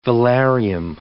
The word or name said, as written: velarium